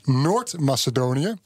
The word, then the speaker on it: Noord-Macedonië: Dan Noord-Macedonië.